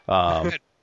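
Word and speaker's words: Padmé: Like, once they capture Anakin and whatever the fuck her name is, Padme, I can't even pronounce it. – Padme, Amidala.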